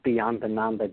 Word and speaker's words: Beyondananda: And you pronounce Beyond Ananda just right. It's an Italian name. It kind of rolls off the tongue. You did good.